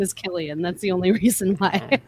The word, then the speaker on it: Cillian: So, Killian. Killian. – I'm gonna call him Cillian because Cillian. I'm an American. I don't pronounce things that way. Okay. Yeah, the K. As an American, I have my right to pronounce everything incorrectly.